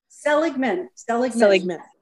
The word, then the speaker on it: Seligman: And I'm not sure if I'm pronouncing your last name correctly there, Paula. I should have asked beforehand. So is it Seligman? – Seligman. – Sounds yes. Good.